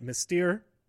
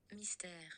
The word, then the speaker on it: Mystère: And uh, we were both wrong because uh, in French, I guess if I just knew French, I would. This is like a stupid, stupid question anyway. I would just know this. But uh, here we go. Mystère. Mystère. Mystère. Mystère. So, uh, yeah, that's how you pronounce it.